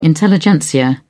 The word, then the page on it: intelligentsia: British English